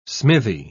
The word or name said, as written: smithy